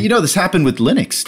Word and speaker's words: Linux: Linux. – Hello, this is Linus Torvalds, and I pronounce Linux as Linux.